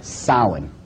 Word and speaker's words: Samhain: This is properly pronounced in the ancient Gaelic as Samhain.